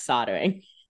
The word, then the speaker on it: soldering: It's pronounced soldering. – Okay. I didn't know. I figured you'd correct it. It has the L in it, but it, you know.